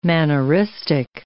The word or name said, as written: manneristic